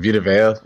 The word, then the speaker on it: Vita Vea: Is it Vita V or Vita Vea? – I mean, I don't know. I'm never going to be the guy that pronounces names wrong. Vita Vea.